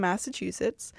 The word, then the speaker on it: Massachusetts: Massachusetts. How do you say it? – Massachusetts. – Okay, yeah. – So it was in Massachusetts, which don't ask me to point that out on a map because...